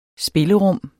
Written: spillerum

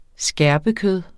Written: skærpekød

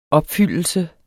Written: opfyldelse

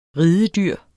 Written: ridedyr

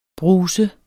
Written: bruser

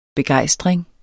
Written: begejstring

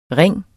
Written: ring

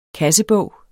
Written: kassebog

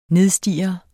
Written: NIDSTIR

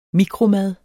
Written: mikromad

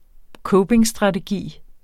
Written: copingstrategi

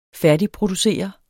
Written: færdigproducere

